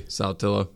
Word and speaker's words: Saltillo: In Mexico, it's Satillo. There's a Satillo, Mexico. Okay. But if you're from Saltillo, it's... How do you pronounce it? – Saltillo. Saltillo. – There's an A on the end. – Saltillo. Okay. – Saltillo, Mississippi.